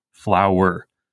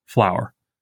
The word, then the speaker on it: flower: In line 4, for instance, we are probably tempted to read a second-foot anapestic substitution, because we pronounce flower as two syllables, flower. But here, in order to keep the rhythm iambic, Bridges wants us to pronounce it as one syllable, flower.